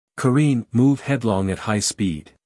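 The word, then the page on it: careen: uk kəˈriːn; en-us kəˈriːn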